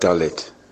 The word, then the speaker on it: dalet: you pronounce it dalet, it's the D sound in Hebrew, it's the fourth letter of the Hebrew alphabet, just like in the English alphabet, but it's not the same.